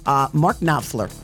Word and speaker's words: Knopfler: Mark Knopfler from Dire Straits.